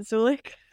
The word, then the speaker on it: Zulich: So my name is Sydney Zulich. It's spelled Zulich. Please go with me here and pronounce it Zulich for anyone who's confused.